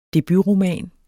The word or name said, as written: DEBUTROMAN